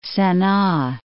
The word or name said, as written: Sana